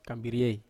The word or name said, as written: kambiriei